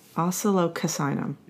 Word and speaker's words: Oscillococcinum: How do you say it? Oxalococcinum. – And I say oxalococcinum. I'm sure I say it wrong. – Oxalococcinum. – Oscillococcinum. – Yeah.